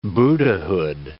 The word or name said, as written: Buddhahood